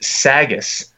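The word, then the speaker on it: Sagesse: And his team is pronounced Sagas in Lebanon.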